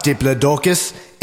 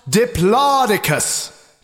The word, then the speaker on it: Diplodocus: It's not Diplodocus, it's pronounced Diplodocus.